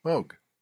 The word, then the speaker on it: Moog: Moog. – Okay. – Moog. I said Moog for 20 years. – Okay. – And until it was established that I was wrong.